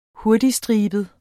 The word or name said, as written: hurtigstribet